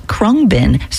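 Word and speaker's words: Khruangbin: And apparently it's pronounced Krungbin. So if you just pretend the A isn't in the word, then you should be able to figure out how to pronounce it.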